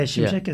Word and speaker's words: Simsek: Yeah, Simsek is good. Like, I actually, I for a long time, I have been hearing Simsek. So Simsek sounds awkward to me.